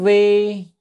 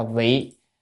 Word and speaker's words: vị: Đản vi nhiêu ích trung hạ nhị thừa, chữ đản là chỉ hay là nhưng. Nhưng vì chữ chữ này phải đọc là vị.